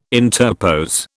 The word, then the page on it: interpose: British English